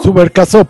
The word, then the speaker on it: Subercaseaux: a ver si puedo pronunciar el nombre, Subercasó, eh, Subercaseaux, ¿no? – Subercasó por ser eh, francés, Subercasó, ah, Benjamín Subercasó, y hace él también una biografía de Chile.